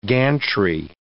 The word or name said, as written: gantry